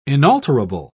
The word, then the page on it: inalterable: British English